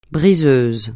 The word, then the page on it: briseuse: francais